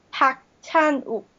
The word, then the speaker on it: Park Chan-wook: So, like, that movie is directed by Park Chan-wook, or Park Chan-wook. I hate that sound. – All right. Well, I'm gonna ask you for my own benefit once again to say the correct pronunciation. – Park Chan-wook.